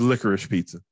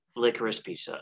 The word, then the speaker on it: Licorice Pizza: La Crosse Pizza. – Oh, it's Licorice Pizza. – Licorice Pizza, okay.